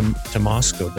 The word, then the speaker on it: Tymosko: Uh, we have Shannon to Tymosko.